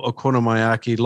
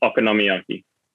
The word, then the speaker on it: okonomiyaki: I've got campfire mussels, coastal okonomiyaki, lobster oil, octopus dumplings, or takoyaki. I think it's takoyaki and okonomiyaki.